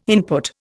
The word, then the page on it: input: Indian